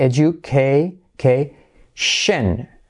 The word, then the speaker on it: education: Edu, edu, fijaos cómo suena esa D, edu, k, shen.